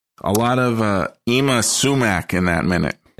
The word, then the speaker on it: Ima Sumac: I did look up the pronunciation. It is pronounced Ima Sumac, okay.